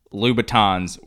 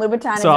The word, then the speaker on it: Louboutin: I'm also the guy who thought that Louboutins were pronounced Louboutins, because I was like, oh, it can't sound like Louis Vuitton.